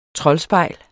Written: troldspejl

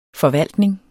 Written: forvaltning

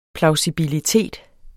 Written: plausibilitet

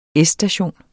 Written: S-station

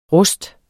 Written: rust

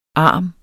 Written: ARMT

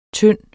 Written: tynde

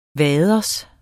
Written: waders

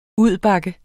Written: udbakke

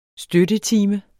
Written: støttetime

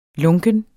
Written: lunken

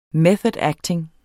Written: method acting

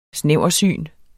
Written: snæversyn

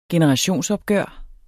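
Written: generationsopgør

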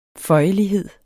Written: føjelighed

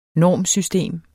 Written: normsystem

